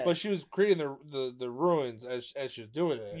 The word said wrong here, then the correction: rune: It's rune. – Ruin? – Rune. – Rune. – Rune. – Like runescape, not ruinscape.